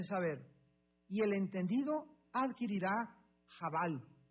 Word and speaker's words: chabal: Cuando alguien se acerca a ti para darte un consejo en privado sin que los demás oigan, es sod, S-O-D de Daniel. En tercer lugar tenemos chaval. No sé si la C-H, creo que se pronuncia jabal, ¿verdad? Jabal es la pronunciación, se escribe C-H, A-B-A-L, jabal.